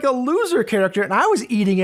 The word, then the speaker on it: Zher: It's spelled L-O-U, and the last name is spelled Z-H-E-R. But when you pronounce it, it's basically loser.